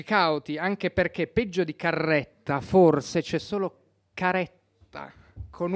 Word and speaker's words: Carretta: Perché il cognome è di origine lucana, quindi la E è aperta, Carretta. Recentemente però ho provato, siccome non sono soddisfatto di questo cognome, ho provato a pronunciarlo secondo la dizione, cioè con la E chiusa.